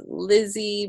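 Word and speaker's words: Lise: the Lizzie part is confusing because it's L-I-S-E, but it's pronounced Lizzie because it's like a Portuguese nickname, and the S in Portuguese in the middle of a word makes a Z sound, and the E just makes an E sound, so for people that have a tough time, I always say just think L-I-Z-Z-I-E, and I also go by L-I-Z-Z-I-E as well.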